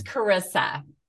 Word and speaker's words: Charissa: Thanks it's Carissa. – Oh, Carissa. Okay, sorry. – That's okay, because it's spelled with an H. So it's the Italian pronunciation.